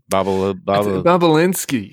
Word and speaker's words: Bobulinski: Bobulinski? – Bobulinski.